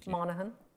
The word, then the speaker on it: Monaghan: Monaghan.